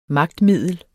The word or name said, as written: magtmiddel